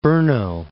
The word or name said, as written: Brno